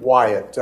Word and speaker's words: Wyatt: K-E-E-N-A-N, last name Wyatt, W-Y-A-T-T.